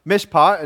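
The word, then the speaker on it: mishpat (מִשְׁפָּט): The Hebrew word that's used there is pronounced mishpat, and if you can read Hebrew, there it is.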